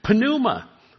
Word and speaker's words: pneuma: But in the Greek, you always pronounce the P, pneuma.